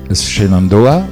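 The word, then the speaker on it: Shenandoah: Ça s'écrit Shenandoa, ça se prononce Shenando.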